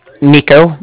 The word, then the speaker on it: Niko: Nico. That's spelled N-I-K-O.